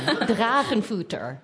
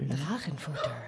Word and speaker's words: Drachenfutter: Drachenfutter. – Could you say it like... Kind of say it softer? – Drachenfutter.